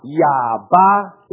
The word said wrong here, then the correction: 亚哈: Yaha. – Yaba. Yaba. – Yaha. – Oh, Yaha. – Yaha. – English is Ahab.